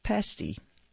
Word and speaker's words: pasty: From Michigan, we have pasty. It rhymes with nasty, not tasty.